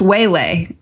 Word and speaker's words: Uele: I'm just really excited about this one because she, I think, gave us a pronunciation of her name, which is spelled U-E-L-E, but it's pronounced Wele.